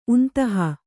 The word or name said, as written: ಉಂತಹ